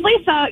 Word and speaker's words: Lesa: So my name's Lisa. You would think L-I-S-A, but no. My dad insisted that my name be spelled L-E-S-A.